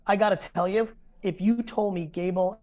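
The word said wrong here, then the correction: Daboll: how does he pronounce his name, the Giants coach? – Dable. – Yeah, Dable. Yeah, I was putting G in it.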